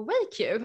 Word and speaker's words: Weiq: men eh, du är ju co-founder och CTO på WakeU. Vänta, uttalade jag det nu, rätt nu? ja, vi, vi, säger Wake.